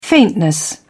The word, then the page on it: faintness: British English